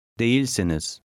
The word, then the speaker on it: Değilsiniz: Put the stress on the second syllable. – Değilsiniz.